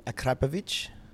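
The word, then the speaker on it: Akrapovič: Yeah, this guy from Slovenia who lives next, just down the road from Akrapovic.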